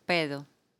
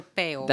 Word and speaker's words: pedo: Some people say pedo or peo.